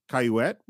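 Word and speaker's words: Cayouette: We also meet Candy's sister, which is Laura Lee Cayuette, rhymes with bassinet.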